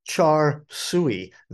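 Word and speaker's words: char siu: It could be char sui uh, or it could be pronounced something totally differently. Uh, it's C H R C H A R. That part's pretty easy, and then S U I sweet, and that is a roasted pork, a Cantonese style roasted pork.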